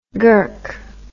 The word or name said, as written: gurk